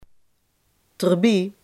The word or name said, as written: erbij